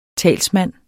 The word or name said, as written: talsmand